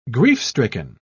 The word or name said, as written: grief-stricken